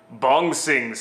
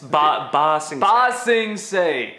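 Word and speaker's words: Ba Sing Se: Again, Bong Sing. How do you say it? – Bong Sing Say. It's Ba Sing Say. Bong Sing Say.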